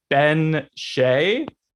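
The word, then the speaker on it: bean sí: So from the research that I found, it's from Old Irish, and the original pronunciation is the Ben Shay. Turns into the banshee.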